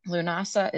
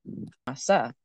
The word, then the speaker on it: Lughnasadh: Lunasa is spelled nothing like it sounds. It's Lughnasadh, but it's Lunasa.